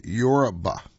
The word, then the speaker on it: Yoruba: And by the way, in the word Yoruba is spelled Y-O-R-U-B-A. Now, I am Americanizing it a little bit in my pronunciation. They're more likely to say Yoruba. Yeah, put a little more on that U, but just to give you an idea.